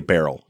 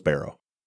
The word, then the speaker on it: barrow: That's how we'd say it in Michigan. We say barrel, even though it's barrow. I know it's barrow.